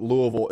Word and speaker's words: Louisville: And by the way, as a man with ties to both Kentucky and Tennessee, I will tell you that Louisville, and that's how you pronounce it, Louisville is 100%.